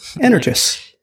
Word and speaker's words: Energous: And this week, Energous, I think is how you say it. – That's how I say it. Energous. – It's like energy plus us.